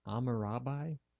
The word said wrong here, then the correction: Hammurabi: Hammurabi. – Did they have such thing as silent letters back then? – I wasn't completely omitting the sound. I was. – Oh, I didn't hear it. – Hammurabi. Okay.